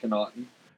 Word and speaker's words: Connaughton: Pat Connaughton.